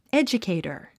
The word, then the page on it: educator: édʒukèitər; エジュケイタァ